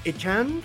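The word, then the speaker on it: Achane: ¿Va a atacar este Raheem Monster y Dovon Echan? Porque ya también nos corrigió cómo se pronuncia. Echan.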